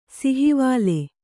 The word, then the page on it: ಸಿಹಿವಾಲೆ: sihivāle